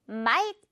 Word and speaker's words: might: 역시 GH는 발음상 빼버리고요. might 이라고만 해주면 되죠.